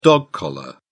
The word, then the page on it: dog collar: British English